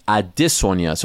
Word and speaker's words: Adesanya: Everyone calls him Adesanya. But I noticed that he said his name a different way. And so I asked him off to the... I was like, is everyone saying your name wrong? He said, yes. It's Adesanya.